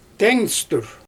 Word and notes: teeŋstyr: Xyzyl

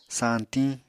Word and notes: sañtiñ: Apatani

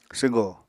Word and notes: swgo: apatani

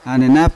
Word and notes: anenap: Yawa; Unat

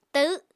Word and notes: tahw7: Apatani

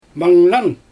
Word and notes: mangnan: Xyzyl

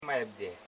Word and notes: maibde: maib.de; Koro

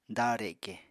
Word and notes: dareke: apatani

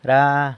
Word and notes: ra: sanlucasquiavini

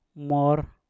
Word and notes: mor: Mpur

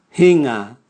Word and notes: hinga: Raga

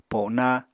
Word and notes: pona: Apatani

